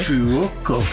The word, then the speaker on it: Fuoco: They have a store in Freshwater Village called Fuoco Pizza. I'm not sure if I pronounced that correctly. – You have to say that quite carefully. – Fuoco, which is Italian for fire.